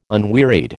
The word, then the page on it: unwearied: American English